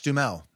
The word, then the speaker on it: Duhamel: Oh, Josh Duhamel is how you say it.